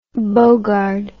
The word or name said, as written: Bogarde